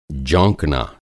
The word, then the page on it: जौंकना: Hindi